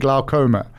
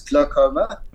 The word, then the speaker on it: glaucoma: And how did I do with pronouncing glaucoma? – You did exceptionally well. It is glaucoma. It's not dragged out.